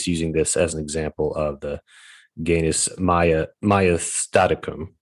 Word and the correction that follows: maiestaticum: Finally, yeah, maiestaticum.